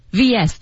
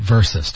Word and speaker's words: versus: 所以你在看到这样的字的时候，你不能读成 Mark vs 晶晶、 right. – 不可以，你必须读成 versus。 – Yeah, say versus, don't say vs。